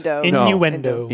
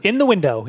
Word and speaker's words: innuendo: We're going to have to work on pronunciation of innuendo. – Innuendo. – Innuendo. – In you. – In the window. Yep. – In you. – No, D.